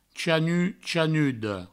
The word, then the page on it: chanut, chanuda: chanu, chanudo; provençal